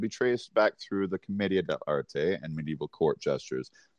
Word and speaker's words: Commedia: Commedia.